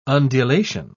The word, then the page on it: undulation: British English